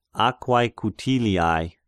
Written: Aquae Cutiliae

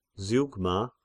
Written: Zeugma

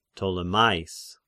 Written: Ptolemais

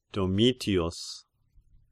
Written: Domitius

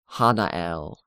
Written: Hanael

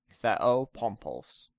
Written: Theopompos

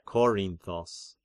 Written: Korinthos

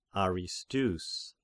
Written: Aristeus

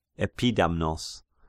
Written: Epidamnos

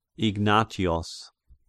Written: Ignatios

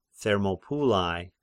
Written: Thermopylai

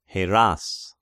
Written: Heras

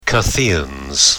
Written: Cutheans

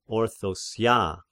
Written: Orthosia

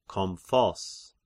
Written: Kompsos

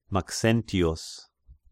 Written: Maxentius

